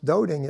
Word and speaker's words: doting: Written out, it looks like dotting, as in dot the I's and cross those T's. But when pronounced doting, it means excessively fond, adoring, affectionate, showing that you love someone very much.